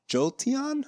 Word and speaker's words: Jolteon: And I believe the pronunciation for my last one is Jolteon. I think that's how you say it. – That sounds right to me.